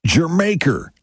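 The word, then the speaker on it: D'yer Mak'er: The way you pronounce that song is Jermaker, like Jamaica, but Jermaker. Yeah, I know it's spelled Dyer Maker, but that's not how you say it.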